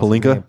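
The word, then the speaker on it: Pelinka: I can't pronounce Palinka. – Palinka. I always forget his name. Palinka.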